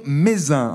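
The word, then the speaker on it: Mézenc: Le sommet du mont Mézin, alors il y a un C à la fin, mais je crois qu'on ne le prononce pas.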